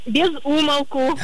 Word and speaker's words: без умолку: В разговорной речи можно услышать разное произношение все-таки, но верным таки является лишь одно. И оно с ударением на первый слог. Поэтому правильно говорить еще раз. Как? – Без умолку.